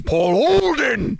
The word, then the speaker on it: Paul Olden: yesterday, you're saying it wrong. It's Paul Olden.